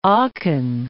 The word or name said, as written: Aachen